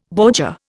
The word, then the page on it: bordure: Indian